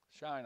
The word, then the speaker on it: Shinar: Am I pronouncing that right? – Shinar. – Shinar, okay. Shinar, shine on harvest moon. That's Shinar. Out of that land went forth Ashur and built Nineveh.